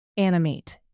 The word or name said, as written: animate